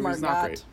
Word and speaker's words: Margot: And it's pronounced Margot.